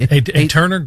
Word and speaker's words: Aeterna: All right, so Aeterna is what it's called. Did I pronounce that right? – Yeah, correct. Aeterna Group. – Aeterna Group, okay. Interesting name. It's spelled A-E-T-E-R-N-A, Aeterna.